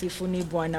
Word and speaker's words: Sifuni Bwana: So we don't pronounce the M. Mm? It's just Sifuni Bwana.